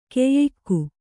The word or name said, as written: ಕಯಿಕೆ